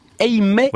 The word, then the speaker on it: Eymet: Votre tour de France à vous ce matin, nouvelle étape entre Eymet et Pau.